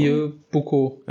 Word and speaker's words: Vieux Boucau: Äh, Challenge äh, Vieux Bocaux. Ist es so richtig ausgesprochen? Vieux Bocaux, ja. genau.